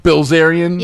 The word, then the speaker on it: Bilzerian: How do you pronounce his name? Dan... – Bilzerian? – Yeah, I'm the Dan Bilzerian of lesbians.